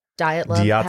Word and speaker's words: Dyatlov: Dyatlov? – Dyatlov. – Dyatlov pass.